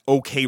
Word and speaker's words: OKeh: And that OK Records is spelled O-K-E-H, OK Records.